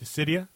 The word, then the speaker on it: Dissidia: Dissidia, however you pronounce it. – Dissidia? – Thank you.